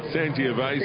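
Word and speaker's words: Sangiovese: Now, it's a shame that San Giovese, the late scratch, or San Giovese. How do you say it? – San Giovese. It's a wine, yes, yeah.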